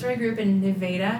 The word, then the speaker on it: Nevada: By the way, everybody, not Nevada. – Yeah, not Nevada. – Not Nevada. – It's pronounced Nevada.